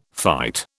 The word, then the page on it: fight: British English